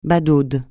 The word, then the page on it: badaude: francais